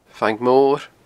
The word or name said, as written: Fang Mor